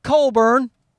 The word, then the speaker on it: Cockburn: Not Coburn. He's a Canadian artist, in case you don't know, whose name is spelt Cockburn, but he calls himself Bruce Coburn.